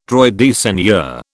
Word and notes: droit de seigneur: British English

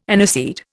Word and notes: aniseed: Indian

